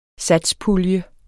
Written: satspulje

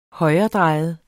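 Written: højredrejet

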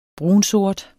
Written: brunsort

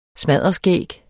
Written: smadderskæg

